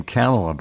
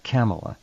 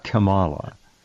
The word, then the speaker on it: Kamala: I've heard her pronounce her own name, Kamala, by the way, just like that, Kamala. It's not Kamala.